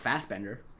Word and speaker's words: Fassbender: I mean, I believe it's Michael Fassbender. Fassbender might be the way it's supposed to be pronounced, but I've never heard anyone say it like that. – Foss is German, yeah.